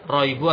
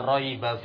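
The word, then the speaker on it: raiba (رَيْبَ): Nah, zalikal kitabu la, bacanya apa? Raibu atau raiba, ikhwan? La raiba fiha.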